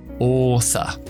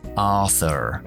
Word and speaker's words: author: Oza. Oza. Esa R al final no lo pronuncio. Ahora te explico por qué estadounidense sí lo pronuncian. Author, author, author.